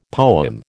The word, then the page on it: poem: American English